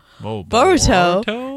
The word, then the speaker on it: Boruto: She is from Burrito. Oh, my God. – Boruto? – No. – That's not even a hard word pronounced. Normally we get it like bad words. That was... Said Burrito. You're hungry.